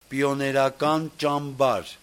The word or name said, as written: pionerakanchambar